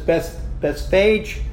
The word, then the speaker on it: Bethphage: It is so interesting that you'll find this word pronounced in so many different ways by so many different preachers. Bethphage, Bethphage, Bethphage. As I understand it, the best way to pronounce it is Bethphage.